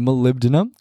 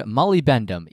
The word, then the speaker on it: molybdenum: The molybdenum. – Cool. – I used to pronounce that word molybendum, even though there's only one D, and it's molybdenum, not molybendum.